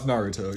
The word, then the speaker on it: Naruto: No, it's Naruto, yeah. – Okay, good. Like, emphasis on the ooh.